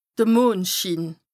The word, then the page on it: de Mondschiin: Bas-Rhin; Strasbourg